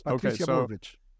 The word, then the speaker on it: Patricia Bullrich: And Patricia, I'm not sure how to pronounce. Yes. – Patricia Bullrich.